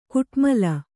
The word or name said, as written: ಕುಟ್ಮಲ